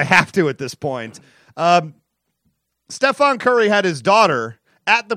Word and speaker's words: Stephen: He's the MVP of the league, so you should say his name correctly. It's Stephon Curry.